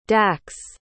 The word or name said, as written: dax